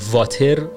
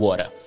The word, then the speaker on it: water: پس توی انگلیسی آمریکایی هست که ما به این شکل میگیم واره